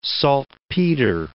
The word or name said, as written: saltpeter